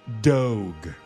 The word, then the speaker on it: Dogue: Well, it's actually pronounced like dog, but if a snooty French person said it, it would be Dogue.